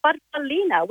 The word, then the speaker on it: farfallina: Well, growing up, as we're little girls, Mum called it a farfallina, which in Italian means a little butterfly.